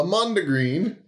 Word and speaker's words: mondegreen: According to Merriam-Webster Dictionary, a mondegreen, is that how you say it? – Yeah. – Mondegreen? Or mondegreen? Is a word or phrase that results from a mishearing of something said or sung.